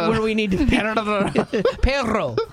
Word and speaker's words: perro: Does he roll his R's? – He does. It's so good. – Perro. – I cannot do it. – Perro. You can't roll your R? – No, not at all.